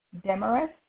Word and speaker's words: Demarest: Demarest. That's D-E-M-A-R-E-S-T.